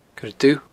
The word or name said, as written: coiredubh